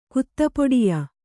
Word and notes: ಕುತ್ತಪೊಡಿಯ: Kannada

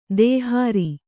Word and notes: ದೇಹಾರಿ: dēhāri

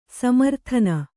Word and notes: ಸಮಾರಾಧನೆ: samārādhane

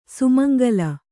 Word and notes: ಸುಮಂಗಲಿ: sumangali; kannada

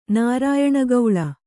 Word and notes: ನಾರಾಯಣಗೌಳ: Kannada

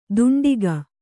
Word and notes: ದುಂಡಿಗ: kannada

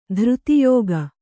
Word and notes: ಧೃತಿಯೋಗ: Kannada

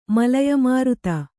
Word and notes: ಮಲಯಮಾರುತ: malaya māruta

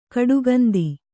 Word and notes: ಕಡುಗಂದಿ: kaḍugandi

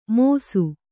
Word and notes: ಮೋಸು: mōsu; kannada

